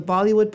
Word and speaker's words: Bollywood: And in Bollywood Jig, Bollywood means, again, we know, to rhyme with Hollywood.